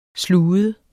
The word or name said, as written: slude